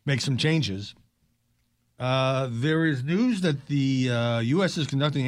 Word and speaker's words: Knewz: This is a headline in News. It's spelled K-N-E-W-Z.